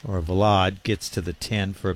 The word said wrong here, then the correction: Vlade: Well, Cleary said Vlade, so that's what we'll go with. Vlade, long A.